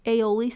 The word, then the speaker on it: aioli: Aioli sauce, okay? A basil aioli. It's spelled A-I-O-L-I. I get kind of tripped up by the I after the A. Aioli.